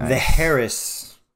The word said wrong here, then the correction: Eris: Eris. I think it's Eris. – Okay. The H is silent.